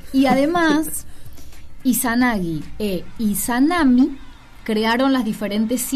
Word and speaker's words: Izanagi: Amaterasu emergió del ojo izquierdo de su padre llamado Izanagi. Lo dije mal. Izanagi.